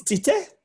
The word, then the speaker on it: Tite: It's T-I-T-E is how it's spelt. – So it would be Tite. – It's Tite? – Tite, yeah. – It's not like with a Brazilian accent, it's not like Chiche or...